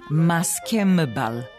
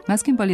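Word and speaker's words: maskenbal: I ni u tom naslovu, niti u naslovu satirične pesme Vojislava Ilića Maskembal na rudniku, a ni u rečniku srpskog jezika, imenica Maskembal nema M, nego N. Kada se suglasnik N nađe ispred usnenih suglasnika, zaista se prema jednačenju po mestu izgovora menja u M. Ali to ne važi za složenice. Maskembal je složenica, pa N na kraju prvog dela složenice ne prelazi u M da bi se sačuvali korenski glasovi oba dela reči.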